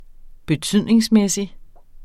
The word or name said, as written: betydningsmæssig